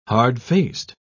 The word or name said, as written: hard-faced